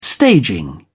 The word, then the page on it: staging: British English